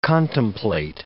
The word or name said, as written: contemplate